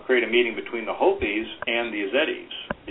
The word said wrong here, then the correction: Yazidi: Azedi, it's Y-E-Z-I-D-I. Yazidi, it's pronounced.